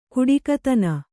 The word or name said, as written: ಕೊಡುಗೈತನ